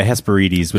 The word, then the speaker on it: Hesperides: I always pronounce that wrong. I say Hesperides. – Oh, yeah. Hesperides would be the Greek pronunciation.